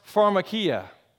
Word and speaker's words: pharmakia: That word is pronounced pharmakia. Sounds like pharmacy for a reason.